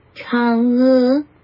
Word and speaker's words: Chang'e: It is CH. I played it several times. I either missed it or it wasn't playing it. I couldn't hear the CH. I heard just an H sound. – Chang'e. – Chang'e. – Chang'e. – Chang'e. It has to come up. The inflection is upwards on both syllables.